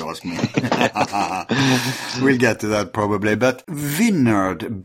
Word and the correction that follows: Wijnand: Berken, I don't know how you pronounce that. – Vinan Berken. – It sounds very Welsh, very Celtic. – He's Dutch. – He's Dutch. – So in Dutch, I think it is Wijnand Berken.